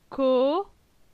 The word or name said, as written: Co